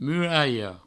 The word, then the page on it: muralha: muraio